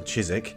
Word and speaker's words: Chiswick: And the correct pronunciation of Yorkshire is Yorkshire, Chiswick is still Chiswick, and the Thames is, of course, the River Thames.